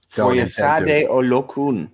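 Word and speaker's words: Foyesade Oluokun: is now the backup behind Foyo Oladise, or I'm probably totally butchering that man's Olokun. – Name. – Foyesade Olokun.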